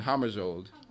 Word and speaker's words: Hammarskjöld: Now, Ingrid, I know he's a Swede and not a Norwegian, but but I didn't know how I was doing with the pronunciation, Hammarskjöld. Okay, you got it.